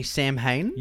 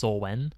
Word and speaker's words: Samhain: I was going to say Samhain. – Yeah, I think it's spelled Samhain, but I think it's pronounced Solwen.